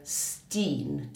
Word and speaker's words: S10: S10 eller S10. Men i och med att han alltid sjunger på holländska så kan man uttala det Steen.